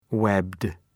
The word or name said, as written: webbed